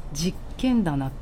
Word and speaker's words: 十軒店: 10 件の店と書いて正式には実剣棚って読むそうです